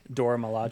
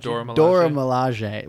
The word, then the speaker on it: Dora Milaje: The Willa Balaji? Did I say that right? – Dora Milaje. – Dora Milaje.